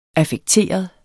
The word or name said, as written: affekteret